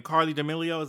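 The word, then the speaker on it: Charli D'Amelio: Carli D'Amelio? Did I say her name right? – Yeah.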